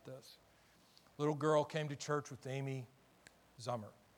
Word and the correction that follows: Zoomer: Zoomer, excuse me. I never say it right.